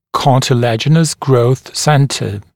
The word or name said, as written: cartilaginous growth centre